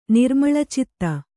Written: ನಿರ್ಮಳಚಿತ್ತ

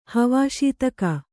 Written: ಹವಾಶೀತಕ

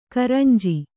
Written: ಕುರಂಜಿ

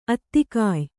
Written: ಆಧಿಕ್ಯ

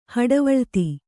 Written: ಹಡೆವಳಿತಿ

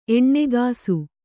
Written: ಎಣ್ಣೆಗಾಸು

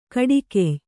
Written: ಕುಡುಗೆಯ್

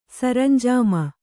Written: ಸರಂಜಾಮ

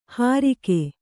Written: ಹರಿಗೆ

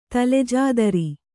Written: ತಲೆಜಾದರಿ

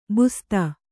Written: ಬಸ್ತಿ

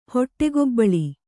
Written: ಹೊಟ್ಟೆಗೊಬ್ಬಳಿ

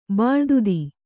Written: ಬಾಳ್ದುದಿ

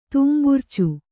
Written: ತೂಂಬುರ್ಚು

